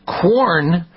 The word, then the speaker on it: Quorn: Quorn, it's kind of a strange name. It sounds like, and you think corn, but it's spelled with a Q. Q-U-O-R-N products are meatless.